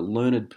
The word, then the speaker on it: learned: Until writing this article, I'd previously researched and found the spelling learnt, with a T, is the preferred Australian English spelling, except for the usage of a learned person with much knowledge pronounced learn-ed.